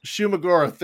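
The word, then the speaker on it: Shuma-Gorath: The first fight where they're fighting not Shuggoth Goroth, whatever, however you say his name. Shuggoroth, whatever. – Shumagoroth, there it is.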